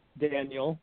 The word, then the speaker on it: Daniel: So it's my full name, Daniel, like everybody, like the book in the Bible. And then Scranton is spelled S-C-R-A-N-T-O-N.